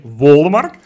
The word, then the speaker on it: Wollemark: Nee, hij heet natuurlijk Patrick Wollemark. We hebben natuurlijk meteen met Sjoerd gevraagd hoe we het uitspreken. Hè? Die, ja. Dus het is Wollemark. Ja, dit Wollemark.